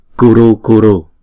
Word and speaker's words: kuru: Tiếng Phạn chúng ta đọc là kuru kuru. Chữ K này chúng ta đọc là cờ. Và chữ U này chúng ta đọc một nhịp kuru kuru.